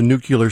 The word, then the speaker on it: nuclear: That word is a two-syllable word. – The thing is, Carter was a nuclear scientist.